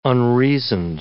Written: unreasoned

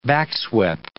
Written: backswept

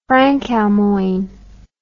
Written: frankalmoign

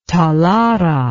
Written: talara

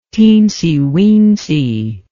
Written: teensy weensy

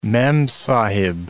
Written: memsahib

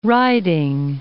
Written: Riding Mountain National Park

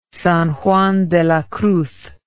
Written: San Juan de la Cruz

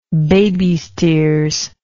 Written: baby's tears

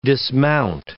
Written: dismount

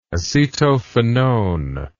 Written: acetophenone

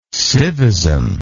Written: civism

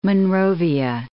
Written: Monrovia

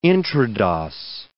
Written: intrados

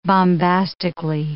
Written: bombastically

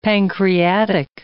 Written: pancreatic

